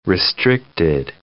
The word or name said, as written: restricted